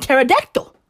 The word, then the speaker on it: pterodactyl: Just like the P on pterodactyl.